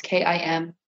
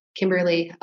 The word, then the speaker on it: Kimberleigh: So it's Kimberleigh. Kimberleigh, last name Stan, S-T-A-N.